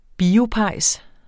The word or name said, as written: biopejs